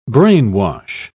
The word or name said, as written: brainwash